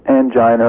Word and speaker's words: angina: Stable angina or angina, depending on how you want to say it. – We use both pronunciations.